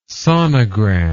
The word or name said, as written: sonogram